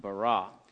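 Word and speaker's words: bara (ברא): It's pronounced bara.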